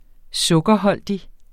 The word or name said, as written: sukkerholdigt